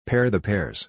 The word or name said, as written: pair the pears